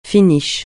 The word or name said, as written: finish